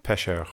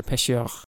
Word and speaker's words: pêcheur: Oui, pêcheur. – Yours was better, pêcheur. And the word for fisherman? – Pêcheur.